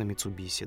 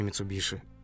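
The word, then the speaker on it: Mitsubishi: Ну, как они у нас говорят, там, машина Митсубиси, да? А не Митсубиши.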